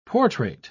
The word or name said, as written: portrait